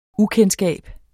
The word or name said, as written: UKENDSKAB